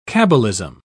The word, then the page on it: cabalism: British English